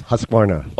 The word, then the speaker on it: Husqvarna: We'll also be talking with the folks over at Husqvarna. Am I saying that right? – That's right. Yes. Husqvarna.